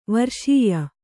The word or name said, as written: ವರ್ಷೀಯ